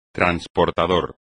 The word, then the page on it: transportador: espagnol